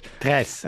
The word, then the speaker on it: Tres: Also gut, die 3 wird jetzt wahrscheinlich eher Spanisch ausgesprochen und nicht Deutsch. Tres. Äh, Antenna Tres, ja.